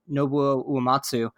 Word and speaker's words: Nobuo Uematsu: Nobuo Uematsu.